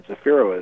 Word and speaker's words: Zafiro: My first name, Frank, and then Zafiro is spelled Z-A-F-I-R-O.